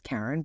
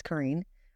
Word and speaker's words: Karin: So my name is Karin. It looks like some people mispronounce it as Karin, but it's Karin.